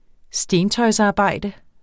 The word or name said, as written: stentøjsarbejde